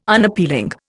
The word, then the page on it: Unappealing: Indian